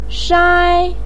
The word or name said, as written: shai1